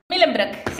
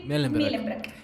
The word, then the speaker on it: Mühlenbrock: Millenbrock, sí. Millenbrock. – ¿Es alemán el apellido? ¿O es de, vaya, de esa zona de Europa, me imagino? – No, sí, alemán.